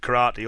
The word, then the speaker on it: karate: which is pronounced either te or de, which gives you the readings of karate or to de.